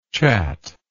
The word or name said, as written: chattable